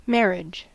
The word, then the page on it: marriage: American English